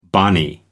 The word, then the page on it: bonny: American English